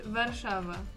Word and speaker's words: Warszawa: Or in Polish, Warszawa.